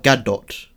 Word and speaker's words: Gadot: Gal Gadot. – Gal Gadot. There's a T at the end, mate.